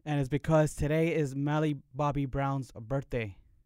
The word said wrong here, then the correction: Millie: Millie or Melly? – However you pronounce it. – Woo. Well, it's Millie.